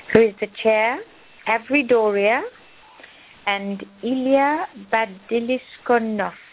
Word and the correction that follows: Bazlyankov: Please help me with your pronunciation of your surname, Ilya. – Bazlyankov. – Bazlyankov. – Yes.